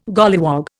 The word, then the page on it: golliwog: Indian